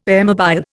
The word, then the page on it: airmobile: Indian